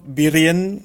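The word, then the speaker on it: Wirén: Vamos a escuchar de Doc Byrien, se escribe W-I-R-E-N, su serenata para cuerdas, opus 11 en 4 movimientos.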